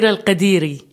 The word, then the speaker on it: El-Khadiri: Munira El-Khadiri. – Not so, I thought it was more harsh with a kh. – No, it's a letter in Arabic, kh, which doesn't exist in English.